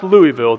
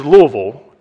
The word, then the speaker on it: Louisville: And when you move to Kentucky, if you move to the Louisville area, they will quickly let you know that it is not pronounced Louisville, that it is Louisville, and you are definitely from out of state if you pronounce it any other way.